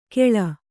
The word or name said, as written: ಖೇಳ